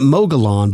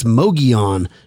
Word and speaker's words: Mogollon: We were pronouncing it Mogollon, but yeah. I believe it's Mogollon after listening to Taylor. So I never thought of that, two L's. It's kind of like pollo, not polo for chicken.